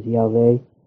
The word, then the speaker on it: Yahweh: Now, how that name, YHVH, occasionally is pronounced as Yahweh, sometimes as Jehovah.